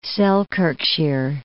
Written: Selkirkshire

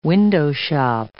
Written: window-shop

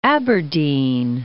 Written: Aberdeen

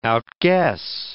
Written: outguess

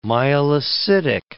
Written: myelocytic

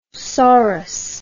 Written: saurus

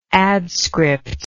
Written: adscript

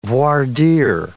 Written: voir dire

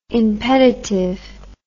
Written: impeditive

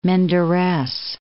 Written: Menderes

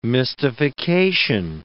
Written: mystification

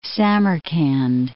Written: Samarkand

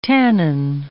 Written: tannin

